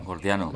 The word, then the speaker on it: Gordiano: Hablabas de la disolución de los nudos antes, sí, y esto sí, me sí, lleva al nudo jordiano, ¿lo he dicho bien? Jordiano. – Gordiano. – Gordiano, sí, eso.